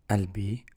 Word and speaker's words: قلبي: The قاف here is not pronounced. Some dialects they do pronounce it, so they say قلبي or بالفصحى قلبي. So قلبي.